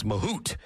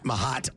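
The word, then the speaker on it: Mahut: And I owe an apology to Nicholas Mahout. I got his name incorrect. It's Mahout, not Mahat, or the pronunciation that BJ. What did you tell me to go with? With the French pronunciation? – Nicholas Mahout.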